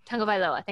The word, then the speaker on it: Tagovailoa: Tagovailoa, thank you.